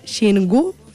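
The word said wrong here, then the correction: Xirgu: Shirgu. – Sí.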